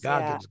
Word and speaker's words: Goggins: I'd rather my uh, and a lot of times, and what he is is this Goggins? Is that how you pronounce his name? David Goggins. – Goggins. Yeah. Goggins.